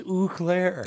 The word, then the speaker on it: Eau Claire: uh, Eau Claire, or however you say it tonight. Eau Claire. I don't think that's too far away. – No, yeah. right. It's Eau Claire.